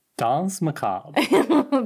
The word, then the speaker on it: Danse Macabre: Actually, he puts on Dance Macabre. Dance Macabre, is that how you say it? say the name of the song for me. I can't say it. – Danse Macabre.